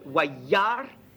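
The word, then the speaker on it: wayar (וַיַּרְא): Ensuite, un yod, avec un point dedans de redoublement, bravo, qui a comme voyelle un A, ça fait waya. Ensuite, R avec E, wayar, et puis le aleph ne se prononce pas, wayar.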